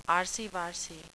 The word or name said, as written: arsy-varsy